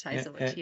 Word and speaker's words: Heazlewood: So let's go into what you actually do and let's go into how does Fleur Heazlewood, is that how you say it, Heazlewood? – Yep, we pronounce Heazlewood, yeah.